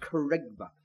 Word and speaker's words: kerygma: That's a word, Greek word pronounced kerygma.